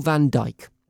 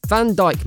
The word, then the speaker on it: Van Dijk: Well, first off, Van Dijk man does not rhyme with Man United by any stretch of the English language.